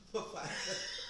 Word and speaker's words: Pfizer: Pfizer. The P is silent. – Right. – I like those words. – The P is not silent. The Pfizer.